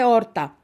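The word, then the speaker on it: Horta: Ο Χωσέ Ράμος Χόρτα. Χόρτα όπως τα χόρτα που τρώμε τώρα που είναι Σαρακοστή. Ναι. Λοιπόν, αλλά προφέρεται Όρτα, γιατί είναι πορτογαλέζικα που μιλάνε στο Ανατολικό Τιμόρ.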